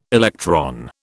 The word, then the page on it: electron: British English